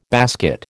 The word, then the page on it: basket: American English